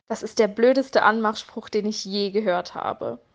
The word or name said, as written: Anmachspruch